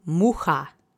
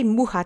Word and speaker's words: mucha: The accent is on the first syllable. Mucha mucha. Mucha. Great. And I hope you remember the CH letter combination sounds like a H. So it's not CH, it's H.